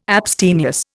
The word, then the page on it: abstemious: Indian